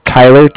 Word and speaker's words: Tyler: My name is Tyler, T-Y-L-E-R, and I'm calling with Cardiff, so it's spelled C-A-R-D-I-F-F, as in Frank, Frank.